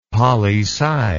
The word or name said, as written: poli sci